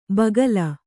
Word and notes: ಬಗಲು: kannada

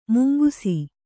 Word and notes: ಮುಂಗುಸಿ: mungusi

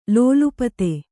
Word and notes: ಲೋಲುಪ್ತಿ: kannada

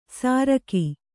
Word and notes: ಸಾರಕಿ: sāraki

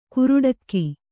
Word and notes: ಕುರುಡಕ್ಕಿ: Kannada

